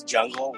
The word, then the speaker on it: Jung: My name is Chris Jung. I know everyone kind of wonders how you pronounce that. It's Jungle without the L-E, Chris Jung.